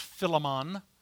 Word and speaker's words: Philemon: I've also heard this pronounced Philemon, for those of you that like steak, because that's what I always think of when someone says that. Filet mignon, there you go, never mind.